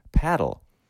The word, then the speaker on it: padel: The sport is paddle, and that's spelled P-A-D-E-L.